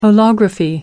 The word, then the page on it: holography: American English